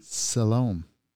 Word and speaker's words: Siloam: S-I-L-O-A-M. – Siloam? – Siloam.